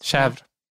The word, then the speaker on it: chèvre: Hur uttalar du det där ordet på CH som är för getost? – Jaha, chèvre. – Ja, precis. – Hur uttalar man det? Där, ja. Chèvre. – Chèvre. – Chèvre. – Nej, det är inte naturligt. – Chèvre. Chèvre kan man säga.